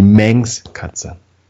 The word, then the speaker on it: Manx-Katze: Ich habe immer von der Minx-Katze gesprochen, das ist allerdings die Manx-Katze.